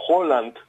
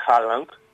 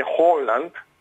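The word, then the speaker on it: Haaland: Χόλαντ, όπως μάθαμε, και όχι Χάλαντ. – Α, έτσι να το προφέρουμε. Ναι, είναι Holland, είναι με νορβηγική προφορά.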